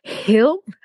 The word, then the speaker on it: Hill: So happy today to be able to speak with Anthony Hill. I have to pronounce the H properly because French people never pronounce the H.